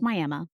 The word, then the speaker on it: Miami: For those of you who don't know, it's spelled like Miami, but it's pronounced Miami.